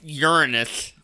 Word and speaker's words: Uranus: It's pronounced Uranus. – Yeah, that's so much better.